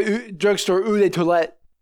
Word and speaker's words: eau de toilette: And this is not just some drugstore eau de toilette.